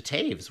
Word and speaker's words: Taves: So I immediately defaulted as of that moment, and henceforth, it's been 32 years since that woeful introduction, to Taves, which is the traditional pronunciation of my dad's last name. And Taves is the traditional Mennonite Low German pronunciation of Tovus, which was, you know, the W's a V.